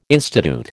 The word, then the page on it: institute: American English